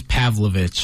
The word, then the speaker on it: Pavlovich: It's thoughts on Blades versus Pavlicic. – Yeah. – Is that how I pronounce it right? – It was Pavlovich. – Pavlovich, gotcha.